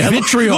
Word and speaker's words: vitriol: Three syllables, man. – Vitriol. – Vitriol. Yeah.